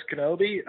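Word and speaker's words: Knobbe: Well, I'm on Twitter at Travis Kenobi. Last name is spelled K-N-O, two B's as in boy, E as in Edward.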